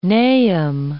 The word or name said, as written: Nahum